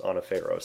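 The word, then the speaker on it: anaphero (ἀναφέρω): Now, the word that's used to bring them up is a word called anaphero. Okay, that's how it's pronounced, anaphero. So you'll see some websites that will say A-N-A-P-H-E-R-O.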